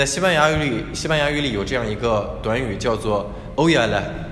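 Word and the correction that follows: Ojalá: 应该是也说错了发音应该是 Ohala，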